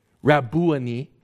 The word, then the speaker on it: Rabboni: In Jewish literature, Rabboni is how it would be pronounced.